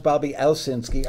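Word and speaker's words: Bobby Owsinski: Commonly mispronounced Bobby Osinski. I was Googling you. I was looking for your number the other day, and I'm on my computer. I'm typing OS instead of OWS because I forgot you had the W in there.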